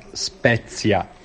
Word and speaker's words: Spezia: Vamos a ver, Paco, que la pronunciación correcta es Spezia.